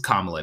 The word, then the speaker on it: Kamala: Kamala Harris's niece has like some kind of company, and she's just like kind of pumping out Kamala merch.